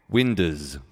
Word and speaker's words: windows: And here's a song called When I'm Cleaning Windows, or as Mr. Formby pronounces it, Winders.